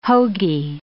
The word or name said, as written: hoagie